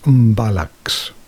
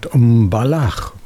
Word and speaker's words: Mbalax: Now, what's this Mbalax? Well, actually, I should have pronounced that Mbalax, probably.